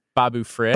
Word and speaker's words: Babu Frick: Boba Frick. No, no, no. Bo- Boba Frick. – How you say his name? – Babu Frick. Babu Frick. Babu Frick. yeah. right. Babu Frick.